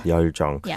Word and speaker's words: 열정: Yeah, it pronounced a bit like 열정. – 열정. – Yeah. – like we said, it's not how it's spelt.